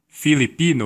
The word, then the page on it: Philippines: American English; /ˈfɪlɪpinz/